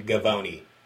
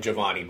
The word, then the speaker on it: Givony: So if you want to believe Zach, it's pronounced Jonathan Givony. I've called him Giovanni before.